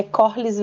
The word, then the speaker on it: Corlys: E eu percebo que ali o Colaris tá meio que pensando assim, putz... – É, é amigo, que eu falei, eu falei falei errado, o nome dele é Corlys Velaryon. Corlys.